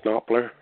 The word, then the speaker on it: Knopfler: I'm a big fan of Mark Knopfler from Dire Straits, if I'm pronouncing his name right. – Mark Knopfler, yeah.